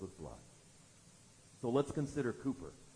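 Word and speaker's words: Cowper: Now, if you looked at that name in your hymnal, you think, that's not how it's spelled. Okay, good, it's not pronounced phonetically. That's what the English do sometimes. William Cooper.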